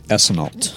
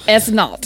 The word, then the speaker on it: Esneault: Am I pronouncing your name right? – Well, we say it Esneault. – Esneault, okay. – Think Epsom salt. That'll get you okay.